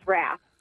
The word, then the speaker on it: Rapp: The kids call me Mrs. Rapp. – Mrs. Rapp. Wait, what? How do you spell it? – Like the music, R-A-P-P.